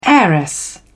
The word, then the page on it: heiress: British English